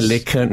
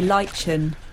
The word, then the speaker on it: lichen: Some say Lickin. No, it's probably not you say it, but I already said Lickin, so I'll stop with it as a pronunciation. Why don't you get off my back? You're having a good time! That's what they want. That's all they want. – I say Lichen. – Yeah, Lichen is probably right.